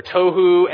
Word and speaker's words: tohu: There's two words in there in the Hebrew that rhyme with each other, tohu and bohu.